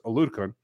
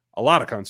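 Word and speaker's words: Oladokun: It is just Michelle, Blaine Gabbert, Patrick, and Chris Aludikun. Aludikun, sorry, I always say that incorrectly.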